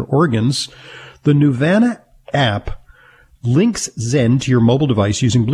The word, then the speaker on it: Xen: It's the Zen by Nuvana. That's spelled X-E-N.